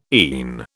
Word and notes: ENE: British English

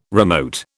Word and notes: remote: British English

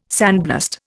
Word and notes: sandblast: Indian

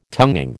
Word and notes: tonguing: American English

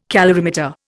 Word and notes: calorimeter: Indian

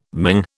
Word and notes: Ming: British English

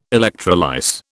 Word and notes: electrolyse: British English

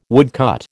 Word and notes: woodcut: American English

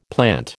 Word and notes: plant: American English

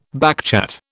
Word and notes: backchat: British English

